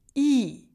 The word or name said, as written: i